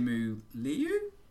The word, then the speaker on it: Simu Liu: Simu Liu? – Perfect.